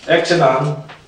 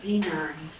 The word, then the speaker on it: xenon: Exenon. – I thought that was pronounced xenon. – Some people pronounce it xenon, but I have a reason for calling it Exenon.